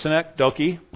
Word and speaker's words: synecdoche: No, like I said, mispronounce it again, it's synecdoche.